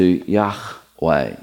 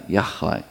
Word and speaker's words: Yahweh: The Lord is a word in Hebrew that has no vowels in it, or at least it has no vowels written in it, it has vowel sounds that sounds very similar to Yahweh, Yahweh.